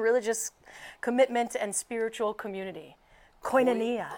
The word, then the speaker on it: koinonia: Koinonia. Most commonly pronounced koinonia.